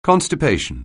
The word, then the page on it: constipation: anglais